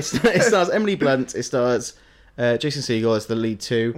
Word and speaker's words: Jason Segel: Because that's not how he's pronounced. He's pronounced Jason Segel. – As in, like, the bird.